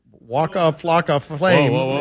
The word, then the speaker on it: Waka Flocka Flame: Waka faka, waka flocka flame. – Waka flocka flame. – That's with an O.